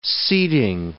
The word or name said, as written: seating